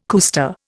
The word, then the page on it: cuesta: Indian